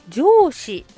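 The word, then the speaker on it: 上巳: 上司の節句、上という字に十二支の御年の御と書いて上司って読むんですね。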